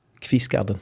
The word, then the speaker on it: Kvistgaarden: Kvistgaarden.